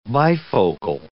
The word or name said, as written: bifocal